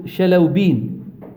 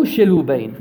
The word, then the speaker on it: الشلوبين: الشلوبين أو شلوبين أو شلوبين يقولون باؤه تنطق بين الباء والفاء